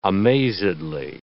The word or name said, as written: amazedly